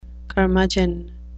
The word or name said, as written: curmudgeon